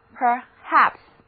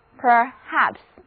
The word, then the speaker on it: perhaps: 所以单词 perhaps 的意思就是可能也许 ,perhaps, 重音在第二个音节读作 perhaps, perhaps。